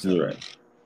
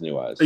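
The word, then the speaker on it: Neweyes: It's New Eyes. I think it's New Eyes. – It is, because it rhymes with Screw Eyes.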